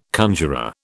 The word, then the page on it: conjuror: British English